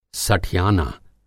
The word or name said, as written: सठियाना